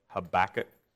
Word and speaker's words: Habakkuk: It's pronounced Habakkuk. Habakkuk. Maybe you think tobacco, and but just not tobacco.